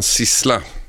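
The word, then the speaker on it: Sizzla: Men i våras så, så kom eh, reggaeartisten Sissla om ni ursäktar uttalet. Mm. – Till, uttalas precis så.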